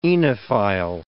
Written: oenophile

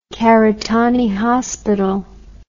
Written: karitane hospital

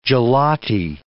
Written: gelati

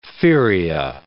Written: feria

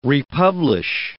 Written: republish

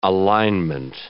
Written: alinement

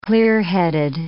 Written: clearheaded

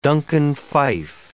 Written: Duncan Phyfe

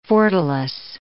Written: fortalice

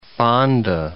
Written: Fonda

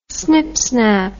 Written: snipsnap

